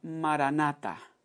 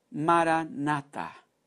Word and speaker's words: maranata: Esa palabra significa en el lenguaje que fue el que hablaba Jesucristo, el arameo significa, o no que significa, sino cómo lo pronunciaban. El término era maranata, maranata.